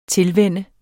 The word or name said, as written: tilvende